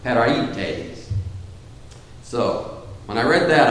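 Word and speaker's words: peraites (πραΰτης): Now, why do I say it like that? Because I listened on the web to make sure I was going to say it right. And when I did, the guy that said it said, peraites!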